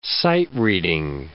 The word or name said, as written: sight-reading